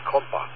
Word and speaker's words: Compas: It's called Compa, C-O-M, let's spell like compass kind of, C-O-M-P-A-S, like French Creole kind of pronunciation.